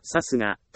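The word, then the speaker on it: 流石: 私はさすがという読みを知らないまま、覚えようとしていました。これがいけなかった。さすがという漢字はさすがと読みます。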